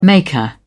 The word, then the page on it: maker: British English